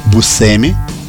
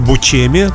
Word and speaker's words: Buscemi: Дело все в том, что фамилия у него – произносится иногда бушеми, сам он ее произносит бусеми. Но ну, можно еще бучеми. Вот, когда он поехал на свою родину, в Сицилию, и спросил у своего дедушки, как же на самом деле произносится наша фамилия, тот ему сказал, наша фамилия Бушеймо.